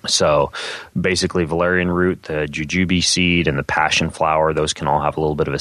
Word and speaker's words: jujube: That's spelled J-U-J-U-B-E. – Jujube? – Jujube, yeah. Yeah, exactly.